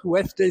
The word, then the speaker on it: WEFTE: WEFDE stands for Water Exercises for the Elderly, and we pronounce it WEFDE, W-E-F-T-E.